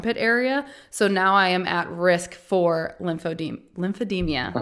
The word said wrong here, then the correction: lymphedema: Lymphedema, yeah. – Lymphedema. – People always add the extra syllable to it, like there's an I, like demia. I'm like, no, it's just lymphedema.